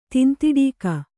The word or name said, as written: ತಿಂತಿಡೀಕ